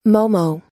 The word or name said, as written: momo